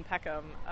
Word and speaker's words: Peckham: My friend Katie, who lives in Peckham, she...